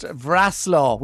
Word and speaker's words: Wrocław: So will we start off with, is it Wrocław? Is that how you say it? W-R-O-C-L-A-W. – Yeah, so that is actually pronounced Wrocław, would you believe it?